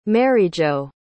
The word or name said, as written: maryjo